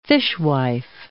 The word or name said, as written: fishwife